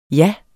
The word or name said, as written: jas